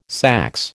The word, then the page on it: saxe: American English